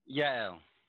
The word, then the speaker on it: Yael: Yaeli. – Y-A-E-L Consulting.